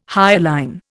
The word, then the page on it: hyaline: Indian